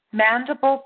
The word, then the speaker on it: mandible: So is this as to the mandible bone? See if I'm pronouncing that right. Mandible bone? – Yes. – Yes. Okay. Wow. All right. Crazy, eh? Okay. So mandible, that's spelled M-A-N-D-I-B-L-E.